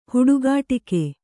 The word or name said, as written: ಹುಡುಗಾಟಿಕೆ